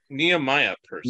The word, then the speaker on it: Nehemiah Persoff: Nehemiah Persoff. – Oh, Nehemiah Persoff. – Nehemiah, thank you.